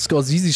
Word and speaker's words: Scorsese: Ja, keine Ahnung, aber du hast Scorsese schon so schön ausgesprochen. Bin ich schon neidisch. – Wie wird der? – Nee, ist richtig. Der ausgesprochen? Ist doch Scorsese, oder? Ich glaube.